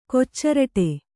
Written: ಕೊಚ್ಚರಟೆ

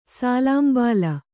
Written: ಸಾಲಾಂಬಾಲ

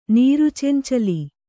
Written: ನೀರುಚೆಂಚಲಿ

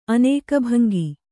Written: ಅನೇಕಭಂಗಿ

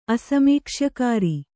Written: ಅಸಮೀಕ್ಷ್ಯಕಾರಿ

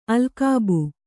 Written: ಅಲ್ಕಾಬು

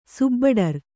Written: ಸುಬ್ಬಡರ್

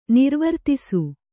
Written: ನಿರ್ವರ್ತಿಸು

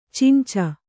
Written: ಚುಂಚು

